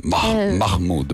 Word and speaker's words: Mahmood: Ma tu lo dici Mahmood o Mahmood? Perché si deve pronunciare, sì, davvero? – Sì, ha detto anche lui, lo vuole pronunciare un po' più all'araba, diciamo.